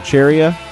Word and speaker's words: Cheria: One of them's called Cheria. It's spelled like chair and area. You squish them together until some letters disappear. You got yourself a portmanteau.